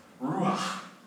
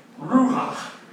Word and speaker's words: Ruach: Ruach. Okay. Say it with me. Ruach. You gotta say it like you're getting a COVID test, right? Ruach.